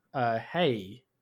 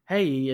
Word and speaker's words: Hei: So we're going to move next to China, which has another bountiful animation scene with a film called The Legend of Hei. I hope I said that correctly because it's H-E-I.